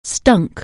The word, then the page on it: stink: anglais